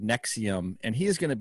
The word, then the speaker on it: NXIVM: I mean, the group Nexium, it's spelled NXIVM, which, by the way, that's red flag number one for me.